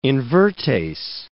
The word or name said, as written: invertase